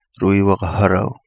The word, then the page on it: Lui Waga Halau: Kapingamarangi